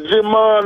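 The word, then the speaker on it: Djimon: Now Kamora Lee and the dude that played in Blood Diamond, Jimon Hansen. Hey, the D is silent, right? – Right.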